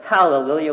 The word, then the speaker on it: Hallelujah: But in the original, it's Hallelujah with a good H sound on there.